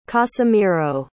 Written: casimiro

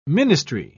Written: ministry

